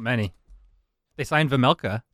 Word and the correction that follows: Vejmelka: Vejmelka. It's a hard J.